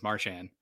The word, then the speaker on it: Marchand: I recently heard him pronounce his own name as Marchand.